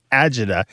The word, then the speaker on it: agida: Argita, but you might see it as Argida, A-R-G-A-D-A, or Agida, A-G-I-D-A.